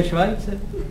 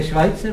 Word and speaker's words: Schweitzer: No to se vyslovuje Švajce. – Co? – To je Švajce právě.